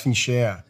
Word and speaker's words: Fincher: I think it's pronounced Fincher, isn't it? – Oh, Fincher, of course.